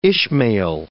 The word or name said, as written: Ishmael